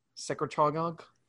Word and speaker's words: secretagogue: So it's orally administered, and it's a growth hormone secretagogue. Is that how you pronounce that? Secretagogue? – Secretagogue. – It's reverse pharmacology.